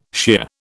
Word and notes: shear: British English

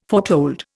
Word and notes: foretold: Indian

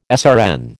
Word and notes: SRN: American English

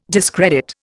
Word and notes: discredit: Indian

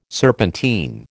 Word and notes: serpentine: American English

